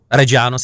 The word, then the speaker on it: Reggiano: I se nevyslovuje, píše Eli. To znamená Parmigiano, Reggiano, Reggiano se říká.